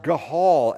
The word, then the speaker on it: Gaal: And then another worthless fellow decides, well, I'm going to make a ploy for leadership, the rebel Gaal, or pronounced Gahal, actually, and under the influence of a few happy hour drinks, he speaks out against Abimelech, because the wicked always challenge power.